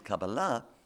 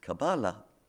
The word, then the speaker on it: Kabbalah: Not least because Shabtai's messianic claim was based on the Jewish mystical tradition known as Kabbalah, or as it's pronounced here in the States, Kabbalah.